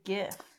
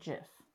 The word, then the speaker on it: GIF: I've never heard it say GIF. – The other side of the argument is that the guy that created GIFs pronounces it JIF. – So then it's JIF.